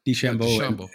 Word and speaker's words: DeChambeau: DeChambeau.